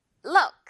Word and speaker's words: look: look 이 정도로 으와 우 사이 정도 우 아주 짧게 어 이런 발음 있죠? look 자 듣고 따라해 주세요.